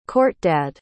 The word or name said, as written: khortdad